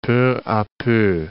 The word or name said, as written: peu a peu